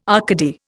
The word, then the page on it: Arcady: Indian